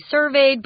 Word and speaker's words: survey: Guys, when it's a noun, please don't say survey. Everyone in Taiwan that I've ever taught English to say survey. Survey is the pronunciation for the verb form, but the noun form is survey. So be careful with that one.